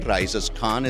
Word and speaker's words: RaícesCon: Raices Con is the event.